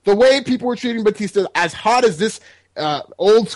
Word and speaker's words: Batista: Yeah, his name wrong, by the way. – Over under the amount of chance. What, Batista? – His name is Batista.